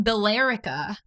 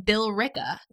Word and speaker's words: Billerica: It's spelled Billerica. – Oh. – But we call it Billrica.